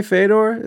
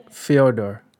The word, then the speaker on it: Fyodor: Am I saying that right, by the way? Fedor? Is that how you say it? – Fyodor. Yes, exactly.